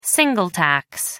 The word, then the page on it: single tax: American English